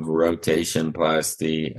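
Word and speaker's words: rotationplasty: Um, but now, having talked about uh, losing your leg and how you adjusted to the surgery of rotation plasty, am I saying that right? – That's yeah, that's right.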